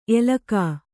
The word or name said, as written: ಎಲಕಾ